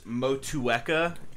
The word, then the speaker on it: Motueka: It's Motueka is how you pronounce it. Ooh. And I believe Motueka.